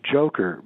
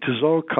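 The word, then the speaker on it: Dzhokhar: So Joker, the kid that helped his brother with the Boston bombings, his name is pronounced Joker, by the way, and everybody's pronouncing it wrong. They're pronouncing it Tzokar or whatever.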